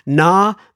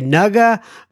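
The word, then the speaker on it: ngā: It's a NGA. I used to say nuga mihi, and I think she wrote me back and said the G is silent, if I am not mistaken.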